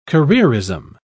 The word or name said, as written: careerism